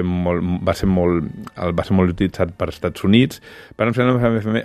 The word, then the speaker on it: Varlam Shalamov: Són relats de Colima de Varian Shalamov. – Varlam Shalamov. – Oh, molt bé, que tu el pronuncies molt bé, que tu la deus admirar.